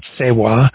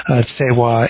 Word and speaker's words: Tsewa: But then for mercy, Tsewa. Tsewa is, again, obviously not exactly what that looks like. That looks like Rseba or whatever.